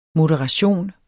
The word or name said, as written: moderation